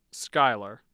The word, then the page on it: Schuyler: skyler